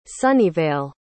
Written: sunnyvale

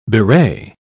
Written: bewray

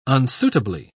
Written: unsuitably

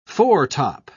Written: foretop

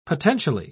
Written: potentially

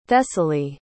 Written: thessaly

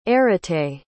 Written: arete

